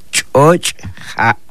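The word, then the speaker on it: Choch ja: Y en lengua maya lo pronunciamos de esta manera: Choch, ja.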